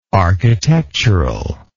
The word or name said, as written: architecturally